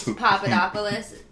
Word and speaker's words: Papadopoulos: Now, I don't know what the basis of the questioning started around, but I can't pronounce that last name. Papadopoulos. – Papadopoulos. It's just close enough that people are going to know who we're talking about. It's a hard name.